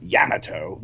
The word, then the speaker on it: Yamato: So highly recommend Star Blazers Space Battleship Yamato, or as they pronounce it in the show, Yamato. I've always pronounced it Yamato, but they call it Yamato, like hammer toes.